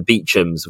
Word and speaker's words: Beauchamp: And, you know, I think we've talked before about certain family names, the Beechams, of course, it's about Beauchamp, but yes, pronounced Beecham, or the it's spelled Cholmondeley.